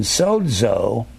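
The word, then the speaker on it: sozo (σῴζω): You'll hear me pronounce it DZ, like in sozo. Sozo, you pronounce the Z DZ. Sozo.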